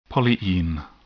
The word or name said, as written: polyene